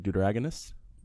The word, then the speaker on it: deuteragonist: So today we're talking about Mike Wazowski, the, I need you to help me pronounce this word, deuteragonist. – Yeah. – Is that right? That's right. – Yeah, secondary protagonist. – Yes, of Monsters, Inc. And then the Deuteronomy? – Protagonist. – Deuteragonist.